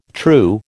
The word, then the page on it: true: American English